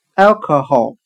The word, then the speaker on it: alcohol: 今天所学的单词 alcohol，酒，酒精。我们就可以通过它的发音联想到汉语的“ 爱哦可喉”，有着一个爱喝酒的喉咙。